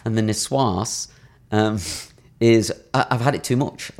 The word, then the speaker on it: niçoise: I mean, it's tuna niçoise all the time at Pret, right? – Yes. – Did I pronounce it right? – You did. Well done.